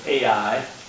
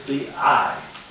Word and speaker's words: Ai: Many pronounce it AI, Matt, but actually, in the Hebrew, it's and it is in the Hebrew. It is the I. It's pronounced just like, you know, in the Scot. Or in the Navy, you say, aye, aye, sir, aye. Well, that's how it's pronounced.